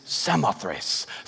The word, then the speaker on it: Samothrace: He goes, we are looking at how to pronounce this word. This word is Samothrace.